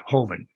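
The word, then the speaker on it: Hoeven: You were, if I say this correctly, you were appointed by Governor Hovind, correct? – Yep.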